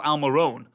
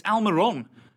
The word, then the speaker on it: Almirón: What kills me is people still say Miguel Almirón. – Right. – It's Almirón. It always has been and always will be Miguel Almirón.